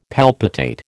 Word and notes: palpitate: American English

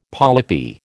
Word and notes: polypi: American English

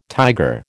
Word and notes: tiger: American English

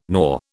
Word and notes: nor: British English

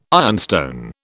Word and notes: ironstone: British English

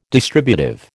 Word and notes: distributive: American English

